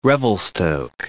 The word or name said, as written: Mount Revelstoke National Park